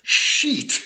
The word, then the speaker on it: Scheat: S-C-H-E-A-T. – Yes. – How do you pronounce that one? – Oh, my God. – We came across that star many, many times, and... Every time we have to be very careful in pronouncing that star. So we came up with Shiat. – I like it. – Yeah. – I like it. – Is that close? – It's much better than the correct pronunciation that's sheet.